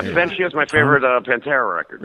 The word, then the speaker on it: in absentia: Tommy Rockstar, TRNN, in absence, in absentia, bot star news. Coming in absentia? How do you say that? – Absentia. – In absentia.